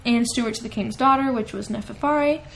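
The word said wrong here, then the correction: Neferure: It's Neferore. That's why I'm saying it wrong.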